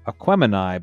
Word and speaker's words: Aquemini: This is Aquemini by Outkast.